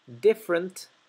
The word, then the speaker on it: different: La primera E de la palabra different se omite, no se pronuncia, es muda. No decimos different, decimos different. Different intentions. Recuerden que shuns. La T-I-O-N se pronuncia shun.